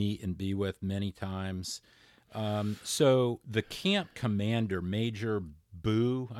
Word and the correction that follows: Bui: B-U-I. We pronounced it Bye.